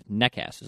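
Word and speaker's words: Nečas: Nekas is how you pronounce this name.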